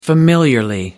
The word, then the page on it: familiarly: British English